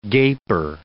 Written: gaper

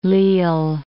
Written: Lisle

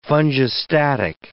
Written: fungistatic

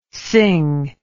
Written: Synge

